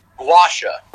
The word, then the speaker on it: gua sha: Gusha? The scraping, right? – Oh, gua sha.